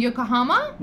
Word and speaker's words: Yokohama: Basis and Yokohama.